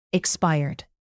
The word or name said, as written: expired